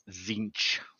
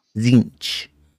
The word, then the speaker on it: Tzeentch: If it's the last chaos god, it's Tzeentch. – Tzeentch.